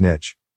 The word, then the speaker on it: niche: Alexa, how do you pronounce N-I-C-H-E? – Okay, here's pronunciations. It is pronounced as niche.